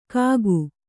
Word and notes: ಕಾಕು: kannada

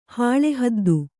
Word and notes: ಹಾಳಿಹದ್ದು: hāḷi haddu

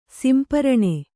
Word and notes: ಸಂಪೂರ್ಣ: sampūrṇa

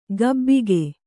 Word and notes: ಗಬ್ಬಿಗೆ: Kannada

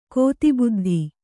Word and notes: ಕೋತಿಬುದ್ಧಿ: Kannada

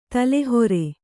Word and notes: ತಲೆಹೊರೆ: tale hore

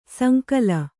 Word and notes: ಸೋಂಕಿಲ್: sōnkil

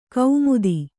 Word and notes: ಕೌಮುದಿ: Kannada

